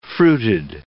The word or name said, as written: fruited